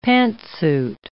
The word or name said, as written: pantsuit